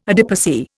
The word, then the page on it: adipocere: Indian